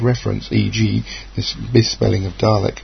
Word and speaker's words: Dalek: It's clearly Dalek. As you listen to a Dalek, he says, Dalek will say, Dalek. Well, the Dalek announcer has made it quite clear how to pronounce the word Dalek.